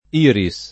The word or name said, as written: Iris